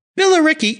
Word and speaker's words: Billericay: I'm revved up and so excited to introduce today's very special guest calling in from Billericay, UK.